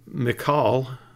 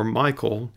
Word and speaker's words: Michal: Well, Michal, or Michael, as it's often pronounced in the West, was the daughter of Saul that David ended up marrying.